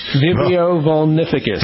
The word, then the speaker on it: Vibrio vulnificus: All right, so Vibrio vulnificus. Is that how you say it? – Vibrio vulnificus.